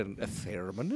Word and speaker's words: theremin: But that's a funny thing about a theremin, isn't it? It's the most erotic sound. – How do you say it? A theremin? – Theremin. – Theremin. – Yes, but don't accent the I too much.